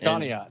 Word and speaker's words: Conneaut: and I believe I'm going to mispronounce this, but Conneaut, Ohio? – Yep, you got it, man, Conneaut.